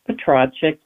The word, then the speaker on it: Petracek: Mispronounced your name. I went to medical school with a gentleman, and his last name is spelled the same way, but he pronounced it Petracek.